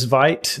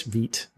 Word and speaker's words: Vite: our product uses Vite these days. Vite, I'm sorry. Sorry, I always say that wrong.